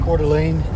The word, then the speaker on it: Coeur d'Alene: Is that how you say it? Coeur d'Alene? Coeur d'Alene? – Coeur d'Alene, yeah.